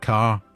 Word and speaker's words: Kerr: I mentioned there Deborah Carr, spelt Kerr, pronounced Carr, for reasons I never quite understood.